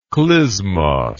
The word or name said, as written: klismos